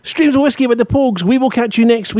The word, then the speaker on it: Pogues: the Pogues, is that the way that you pronounce it, the Pogues? – Yes, that's right, yeah, the Pogues.